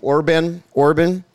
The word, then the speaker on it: Orban: how do you say his last name? Orban. Viktor Orban.